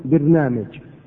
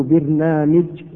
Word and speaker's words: برنامج: البرامج جمع برنامج، إما أن تكسر الباء وإما أن تفتحها، وإما أن تكسر الميم وإما أن تفتحها، فتقول برنامج وبرنامج وبرنامج وبرنامج. فهذه أربعة أوجه لنطق هذه الكلمة وكلها صحيحة.